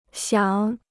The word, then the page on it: 想: xiǎng